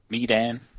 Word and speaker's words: Medan: Like Milan, but Medan.